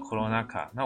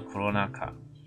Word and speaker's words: コロナ禍: 接下来片假名这边有几个 corona，那还有一个汉字写的灾祸的祸哈，它念作 corona 卡。那我们以前前面有提到，日本人喜欢略称的习惯，把这个武汉肺炎的疫情呢，他直接就把它缩缩写为 corona，还有灾祸的祸这样子，它就代表这次武汉肺炎的疫情，它念作 corona 卡。